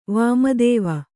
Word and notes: ವಾಮದೇವ: Kannada

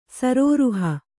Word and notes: ಸರೋರುಹ: sarōruha

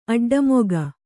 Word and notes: ಅಡ್ಡಮುಖ: kannada

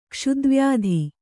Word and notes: ಕ್ಷುದ್ವ್ಯಾಧಿ: Kannada

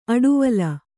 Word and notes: ಆಡುವೊಲ: āḍuvola

